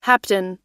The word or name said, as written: hapten